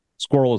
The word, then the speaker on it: squirrel: Squirrel. Squirrel is one syllable.